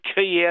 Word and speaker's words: quay: How do you get key out of Q-U-A-Y? – You know what?